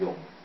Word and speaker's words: Jung: 有一个人叫那个 Carl Jung， actually I think his name is Jung， it's the German pronunciation is Jung，